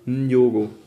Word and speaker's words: Nyong'o: Spricht man das eigentlich mit? – Ja, Nyong'o.